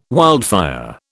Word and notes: wildfire: British English

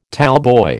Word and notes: tallboy: American English

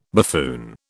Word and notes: buffoon: British English

